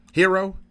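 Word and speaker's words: Herro: How you say it? Tyler Hero? Hero? – Tyler Hero, yeah.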